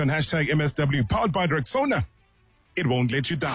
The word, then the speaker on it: Rexona: If you haven't heard, Shield is now Rexona. A brand that you've trusted for years is stepping forward with a bold new move and a bold new name. It's pronounced Rexona.